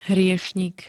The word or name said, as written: hriešnik